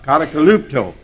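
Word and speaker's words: Katakalupto (κατακαλύπτω): Katakalupto, I guess, would be more properly pronounced. Katakalupto.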